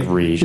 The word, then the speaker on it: Riege: R-I-E-G-E. It's like Rigge.